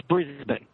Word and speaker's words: Brisbane: And perhaps I should clear up this pronunciation issue. – Okay. – So it's Brisbane.